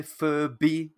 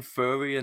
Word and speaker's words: Furby: Please say Furby. Pronounce the B because you're saying Furry, and now I'm just imagining Snoop Dogg as a dog Furby.